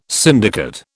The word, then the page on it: syndicate: British English